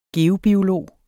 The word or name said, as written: geobiolog